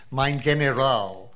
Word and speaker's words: mein General: Liddy always referred to Mitchell as the Attorney General as mein General. Actually makes a point of that with a hard G.